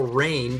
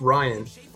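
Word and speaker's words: Rian: All right, it's directed by a guy that you call Rain, but his name is pronounced Ryan.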